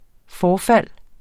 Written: forfald

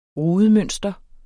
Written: rudemønster

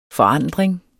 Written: forandring

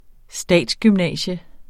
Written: statsgymnasium

